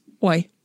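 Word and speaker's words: oj: The plural is indicated by an ending with O-J, pronounced oi.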